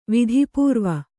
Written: ವಿಧಿಪೂರ್ವ

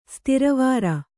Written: ಸ್ತಿರವಾರ

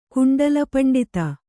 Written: ಕುಂಡಲಪಂಡಿತ

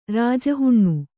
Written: ರಾಜಹುಣ್ಣು

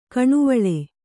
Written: ಕೆನೆವಾಲ್

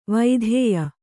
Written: ವೈಧೇಯ

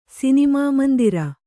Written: ಸಿನಿಮಾಮಂದಿರ

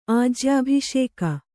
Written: ಆಜ್ಯಾಭಿಷೇಕ

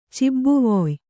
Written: ಚಿಬ್ಬುವೊಯ್

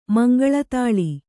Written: ಮಂಗಳತಾಳಿ

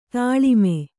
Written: ತಾಲೀಮ

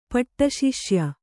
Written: ಪಟ್ಟಶಿಷ್ಯ

